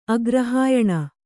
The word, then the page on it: ಆಗ್ರಹಾಯಣ: āgrahāyaṇa